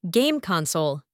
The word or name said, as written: game console